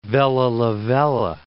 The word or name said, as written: Vella Lavella